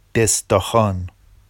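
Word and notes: دِسداخان: desdɑxɑn; Vafsi

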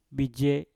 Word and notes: bije: mpur

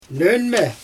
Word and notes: nöñmE: Xyzyl

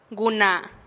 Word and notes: guna: TdVZ

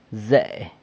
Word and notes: ze: corn on the cob

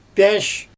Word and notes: pJAAsh: Xyzyl

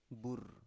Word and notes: bur: Biak

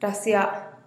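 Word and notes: rasiaa: Teotitlán del Valle Zapotec

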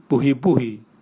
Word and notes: buhibuhi: Raga

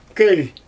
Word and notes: köl: Xyzyl

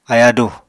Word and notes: ayádù: Apatani

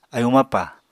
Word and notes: ayúu mapà: apatani

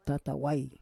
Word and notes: tatawai: Roon

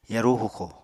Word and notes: yarú huxo: apatani

